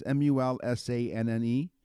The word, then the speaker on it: Mulsanne: M U L S A N N E?